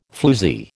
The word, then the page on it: floozie: American English